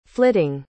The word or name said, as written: flitting